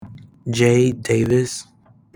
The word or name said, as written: Tronson